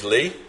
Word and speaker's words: Leigh: So my name's Lee, as it says there, L-E-I-G-H.